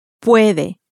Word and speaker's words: puede: Puede.